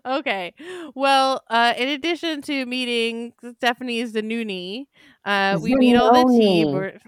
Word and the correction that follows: Zanoni: I'm going to say it wrong every time. I don't know why I think it's Zanuni. Stephanie Zanoni.